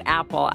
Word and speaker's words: Appel: It's spelled A-P-P-E-L, but we just say it Apple.